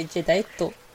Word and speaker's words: Jadeite: I don't think you pronounce it Jadeite. – I mean, it is. If you pronounce it the Japanese way, Jadeito.